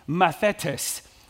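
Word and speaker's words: mathetes (μαθητής): The Greek word for disciple is a word that is pronounced mathetes. Mathetes is how it's pronounced.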